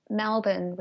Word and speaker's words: Melbourne: So imagine if you spelled Melbourne with a B-I-N on the end, and then that's how we pronounce it.